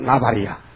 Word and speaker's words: 나발: 나발인데 발음을 잡으면 나발이야.